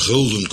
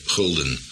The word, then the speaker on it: gulden: Het zal een gouden florijn geweest zijn, vandaar dat wij nog een F schrijven en dan uitspreken, gulden.